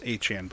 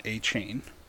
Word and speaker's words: Achane: It is pronounced Achane, boys. It's not Achane.